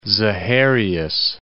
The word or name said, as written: Zaharias